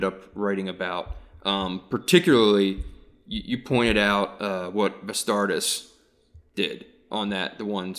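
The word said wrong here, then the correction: Vastardis: Did I pronounce his name correctly? – Vistardis, yeah. – Yeah, yeah. – With a V.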